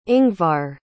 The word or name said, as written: ingvar